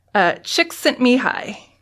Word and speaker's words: Csikszentmihalyi: And it had 174 thumbs up and 2 thumbs down, so I think this is the right way to say say the name. His name is Mihai, that's his first name. Csikszentmihalyi.